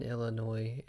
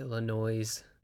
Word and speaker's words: Illinois: I am well aware that the S in Illinois is silent. It is not Illinois.